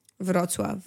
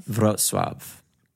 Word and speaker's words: Wrocław: Wrocław. W-R-O-C-L. – Look, all I know, it has a W and it probably has a Z. – It's pronounced how again? – Wrocław. – Wrocław.